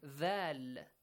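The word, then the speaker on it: Dhal (ذال): Dhal. Z. Not z. Z. Bite your tongue a bit. With the tip of your tongue, you should pronounce it.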